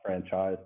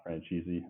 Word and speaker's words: Franchyze: It's F R A N C H Y Z E nine two three, and it's pronounced franchise, not franchisee.